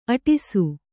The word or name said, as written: ಅಟ್ಟಸ